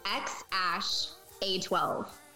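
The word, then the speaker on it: X Æ A-12: Okay, I'll pronounce it for you. It's XASH A12.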